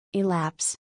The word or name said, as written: Elapse